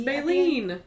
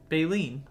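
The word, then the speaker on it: Baylene: Baylin, but it's spelled L-E-N-E. – It's Baylin. – Baylin? – Baylin.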